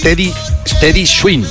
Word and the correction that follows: Teddy Swims: Es Teddy Swims y esta es una canción que hace con Tiago.